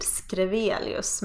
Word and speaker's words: Schrewelius: Egentligen uttalas mitt efternamn med eh, ett s-ljud, Skrevelius, men på grund av eh, stavningen så SCH, Padma SCH.